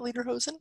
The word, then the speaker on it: Lederhosen: Lederhosen? – Yes.